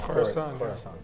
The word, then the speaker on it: Khorasan: Let's talk a little bit about this new group that we're hearing about, Khorasan, or how do we say that? – Khorasan.